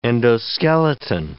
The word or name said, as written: endoskeleton